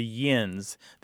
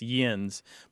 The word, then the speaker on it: yins: it's directly related to the yins. They tend to spell it in Pennsylvania as Y-I-N-S, yins, but it's the same thing, just a slightly different pronunciation and a different spelling.